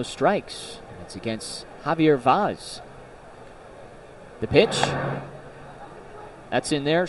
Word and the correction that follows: Vaz: I should say Vaz, I apologize. Javier Vaz. – Rhymes with jazz.